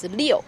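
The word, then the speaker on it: Liu (六): The other two lucky numbers are number 6, that's pronounced Liu, and it sounds a little like Lu, Liu and Lu. Lu in Chinese, it's good luck in your career.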